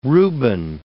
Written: Reuben

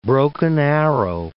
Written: Broken Arrow

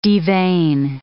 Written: devein

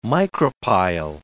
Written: micropyle

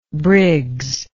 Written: briggsian

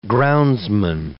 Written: groundsman

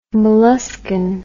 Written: molluscan

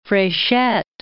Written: Frechette

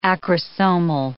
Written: acrosomal

